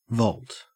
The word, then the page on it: vault: Australian English